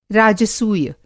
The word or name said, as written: राजसूय